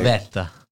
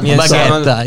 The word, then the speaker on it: gavetta: Gavetta. – Gavetta. That's not good. Sorry. – We said not baguetta. It's gavetta.